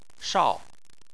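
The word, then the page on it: shao: tone 4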